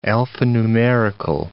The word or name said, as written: alphanumerical